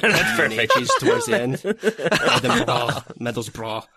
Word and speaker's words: Middlesbrough: And just before we get angry tweets, we should note it's pronounced Middlesbrough. That's how everyone likes to pronounce it.